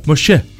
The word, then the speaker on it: Moshe: Vamos a estar hablando de Moisés, que se pronuncia Moshe.